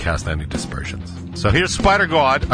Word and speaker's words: Spidergawd: And the band is spelled S-P-I-D-E-R-G-A-W-D, which in my pronunciation would be Spider God.